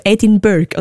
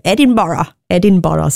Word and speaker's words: Edinburgh: Damals, vor ein paar Jahren, habe ich auch gesagt, ja, irgendwie einen Busfahrer gefragt, wie kommen wir am schnellsten auf Edinburgh? Und dann hat er mich ausgelacht. Edinburgh, sagt man dem.